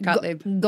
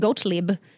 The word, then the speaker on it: Gottlieb: Gottlieb. – Gottlieb.